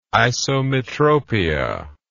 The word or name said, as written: isometropia